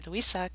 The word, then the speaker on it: Louise: So it's L O U I S E R O S A G E R, um, who friends always call her Louisa because she's yes. from Denmark. and that's how you say it there.